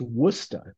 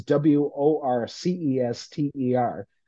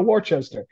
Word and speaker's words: Worcester: and it said it's pronounced Worcester, W O O S T H which is a Massachusetts joke because the the city in the middle of the state is W O R C E S T E R. So you always have people saying Worcester. I want to go to Worcester.